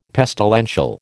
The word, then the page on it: pestilential: American English